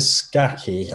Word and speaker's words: Scacchi: Um, and you've also got the great Greta Sarchi. That's how you say it, isn't it? Greta Sarchi. Yeah, Greta Scacchi.